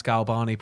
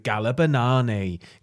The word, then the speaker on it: Galbani: Like, the people can't pronounce Galbani properly, but they're adding so many syllables. It's like, if you were not adding syllables, fine. But it's like, you'll just hear somebody go, Galabanani, Galarimanabriani. And it's like, it doesn't even have a Z in it.